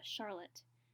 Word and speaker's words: Charlotte: It's Charlotte.